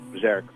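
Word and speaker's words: Björk: Actually, I think her name is pronounced Bjork, but that's beside the point.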